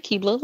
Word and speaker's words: Akkebala: So my name is Ebony Akkebala, like a Akibla cookie or Akibla elf, however you want to pronounce it.